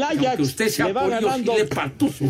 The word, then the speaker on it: Ajax: Dije Ajax, ¿eh? Dije Ajax sea, no Ajax, porque si no van a... – Pues es que, así se escribe, hombre. dije el Ajax, que es el nombre del equipo. El otro es para que, para que limpien este, los sartenes y esas cosas.